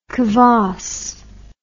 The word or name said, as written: kavass